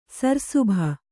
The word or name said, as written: ಸರ್‍ಸುಭ